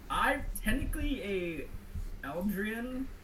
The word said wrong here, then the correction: Eladrin: Eladrin. – Eladrian, my bad.